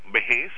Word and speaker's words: vejez: Así que los niños, o sea, se escribe con Z, vejez, pero la pronunciación es con S.